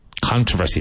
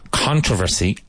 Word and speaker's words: controversy: Controversy. This word has two pronunciations. Controversy and controversy. Now, the much more mainstream and common one is controversy.